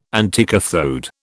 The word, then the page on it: anticathode: British English